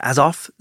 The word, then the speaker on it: Azov: Maybe I've been saying it wrong earlier. Azov, Sea of Azov, and the Black Sea. A-Z-O-V.